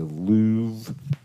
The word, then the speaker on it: Louvre: We're going to see the Louvre. Why does it have an R in it, but you can't pronounce the R? – Because it's French.